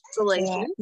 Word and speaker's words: Galatians: It comes from Galatians, am I saying that right? – Galatians.